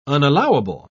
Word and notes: unallowable: British English

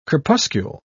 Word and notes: crepuscule: British English

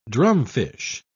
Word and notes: drumfish: British English

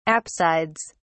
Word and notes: apsides: British English

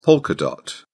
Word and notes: polka dot: British English

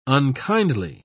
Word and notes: unkindly: British English

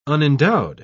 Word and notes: unendowed: British English

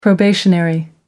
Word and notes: probationary: American English

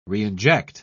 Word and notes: reinject: British English

